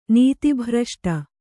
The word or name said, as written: ನೀತಿಭ್ರಷ್ಟ